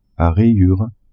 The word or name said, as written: a rayures